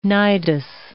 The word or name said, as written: nidus